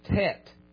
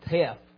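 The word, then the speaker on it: tet (טית): And that particular Hebrew letter there is pronounced tet. Tet. It looks like teth, but it's pronounced tet.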